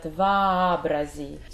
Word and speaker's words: вообрази: Yes, вообрази, and they pronounce like A, and it's very important that you pronounce both o, like a. So it's not vabrazi, but vabrazi.